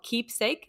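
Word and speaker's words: Qeepsake: is there's a service called Keepsake. It's spelled with a Q, Q-E-E-P-S-A-K-E.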